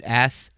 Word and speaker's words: Assayas: It's Assayas, I think is how you say his name. It's Assayas.